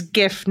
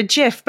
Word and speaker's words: GIF: And it's GIF, no matter what John says, or the founder. the founder calls it a GIF, but it's G is for graphical. It's GIF. – That's It's GIF.